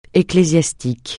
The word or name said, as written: ecclésiastique